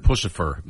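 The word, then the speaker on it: Puscifer: I heard pussifer, yeah. – Yeah. – All right. – That's how it's pronounced.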